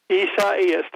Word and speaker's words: Isaias: How do you say the name of the hurricane? – Isaias.